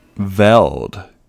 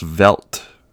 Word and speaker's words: veld: Veld. Actually, it's pronounced Velt. V-E-L-D.